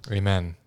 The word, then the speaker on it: Amen: Amen. That's how it's pronounced.